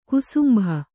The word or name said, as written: ಕುಸುಂಬಾ